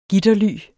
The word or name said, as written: gitterly